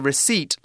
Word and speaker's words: receipt: Now, a receipt, look, it's a tricky word to spell R E C E I P T. We don't mm. pronounce the P, just receipt.